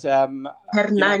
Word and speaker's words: Hernani: It's Hernani, but Hernani, you know, sorry.